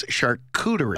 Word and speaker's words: charcuterie: Very close, but we determined it's charcuterie.